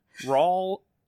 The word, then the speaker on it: Raul: California Democrat Raul. Ruiz faces challenge from Republican Raul Ruiz. – Raul? – No. – R-A-U-L? – No, I said it right the first time.